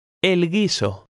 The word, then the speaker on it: guiso: In the combinations G-U-I or G-U-E, the U is silent and the G is hard. Now you read number 18. – El guiso.